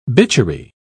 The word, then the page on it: bitchery: British English